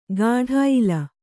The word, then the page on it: ಗಾಢಾಯಿಲ: gāḍhāyila